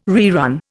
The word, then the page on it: rerun: Indian